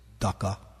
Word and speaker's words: Daksha: Daksha. He has a big service, important service. He has known the word Daksha, as Prabhupada would say, Daka, because in Bengali it's pronounced Daka.